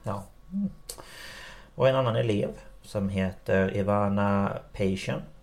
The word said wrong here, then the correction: Peyton: Patien? Peyton? Peyton! Tack! Men ni vet hur det är med mina uttal, jag vet, förlåt.